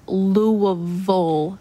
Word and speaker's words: Louisville: They, anything that has a vill at the end, they say vull, vull, like Louisville, Kentucky.